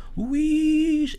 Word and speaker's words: Luís: Por exemplo, eu estou a musicar o meu nome, que é Luís, que é um nome terrível para musicar, porque tem uma sílaba fechada, que é a sílaba tónica. Eu não vou cantar Luís. É horrível para um cantor, porque é um som fechado.